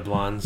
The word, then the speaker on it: Yablans: And that's kind of the film that introduced him into the guy, Erwin Yablans, if I'm saying that correctly. – Yeah, Yablans.